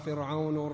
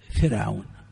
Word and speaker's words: فرعون: أنا أقول للجميع هذا الكلام، كلمتان يكثر الخطأ فيهما، فرعون ومريم. يخطئ كثير من الناس فيفخم راء فرعون ويرقق راء مريم، يا مريم ها، ويرقق راء مريم فيقول يا مريم يا مريم اقنتي لربك. والصواب العكس فيهما، الصواب العكس فيهما.